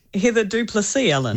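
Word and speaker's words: Heather du Plessis-Allen: Heather Du Plessis Allen.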